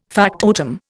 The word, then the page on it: factotum: Indian